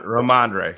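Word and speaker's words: Rhamondre: You know, coming from last game, they did a good job in the against the run against Rodemeyer Stevenson. Is that how you pronounce the first name for the Patriots? Stevenson, the running back. – Oh, Ramondre. Anyway, Ramondre. Yeah. Ramondre.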